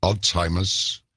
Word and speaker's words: Alzheimer's: Alzheimer's, because like an onion, not necessarily a glass one, but it's not a bad allegory.